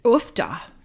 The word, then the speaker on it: uff da: Well, the classic is ufda, ufda, which is spelled U-F-F-D-A.